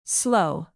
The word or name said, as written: slough